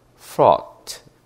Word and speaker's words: fraught: 옛날에는 GHT를 다 읽었겠지만 지금은 안 읽죠. 그래서 묵음이 되니까 fraught, 걱정스러운이란 뜻입니다.